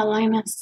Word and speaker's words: Lynas: I think I'm saying that right. L-Y-N-A-S. Linus? – Yeah, Linus.